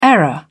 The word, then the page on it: error: British English